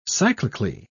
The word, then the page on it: cyclically: British English